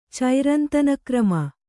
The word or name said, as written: ಚೈರಂತನಕ್ರಮ